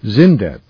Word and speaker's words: Xyndet: There are three Zindet products, a sensitive skin formula cleansing bar, a hair and body shampoo, and a skin balm. Zindet, that's spelled XYNDET is truly unique.